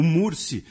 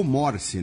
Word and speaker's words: Morsi: O Mursi, ele foi, ou Morsi, né, tem as duas pronúncias,